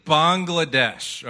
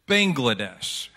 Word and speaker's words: Bangladesh: But I keep saying Bangladesh, all right? And some of you look at it and you probably pronounce it Bangladesh. How many of you do that? Yeah? Because it looks like bang, right? But there's a reason I say Bangladesh, because in their language, the word bang literally translates frog.